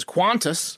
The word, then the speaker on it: Qantas: Australian Airlines, I'm going to pronounce this as Qantas. – Correct.